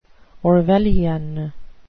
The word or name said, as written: orwellian